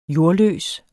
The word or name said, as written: jordløs